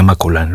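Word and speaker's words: Maculan: Su apellido, Valeria Maculán, se escribe sin acento, pero se pronuncia Valeria Maculán.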